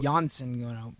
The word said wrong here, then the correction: Johnson: It's not Janssen. It's Johnson. Jacob. – Jacob Johnson. – Johnson. Soft J, hard J.